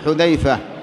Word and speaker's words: حذيفة: حذيفة بهذا اللفظ، بهذا النطق، حذيفة بضم الحاء المهملة، فذال معجمة، فمثنى تحتية كما قال الشيخ، وهذا الشيخ الصنعاني، ففاء هو أبو عبد الله.